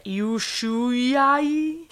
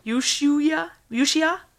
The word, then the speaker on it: Ushuaia: Ushuaia. – Well done. – Thank you. Ushuaia, otherwise known as the prison at the end of the world.